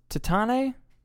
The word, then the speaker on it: Titane: I think it's pronounced Titane or Titane. It means titanium, and I believe it's French.